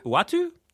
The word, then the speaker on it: Uatu: Uatu.